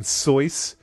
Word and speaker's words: Seuss: I know that he prefers the pronunciation Soyce, but I'm not saying Soyce. I'm sticking with Seuss because his people made my publisher not do what... Or made my potential publisher not buy my book. – Are you kidding me right now? – The musical. – It's truly... The actual pronunciation is Soyce.